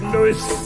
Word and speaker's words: Louis: He prefers his name to be pronounced Louis. – This is Louis.